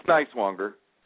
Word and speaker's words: Nyswonger: It's Nyswonger.